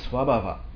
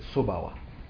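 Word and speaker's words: svabhava: So also that you have that, and then also, ranshin is swabhava. Tibetans pronounce it sobawa. Swabhava, I'm not, probably not pronouncing it correctly. Am I pronouncing it correctly? – Swabhav. – Oh, there it is, right there. Sounds sexier. Swabhav.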